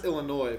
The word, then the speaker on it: Illinois: It's pronounced Illinois, but it's spelled Illinois.